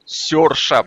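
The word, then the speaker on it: Saoirse: Почему оно пишется так, а читается вот Серша? Потому что ни один американец его так не прочитает.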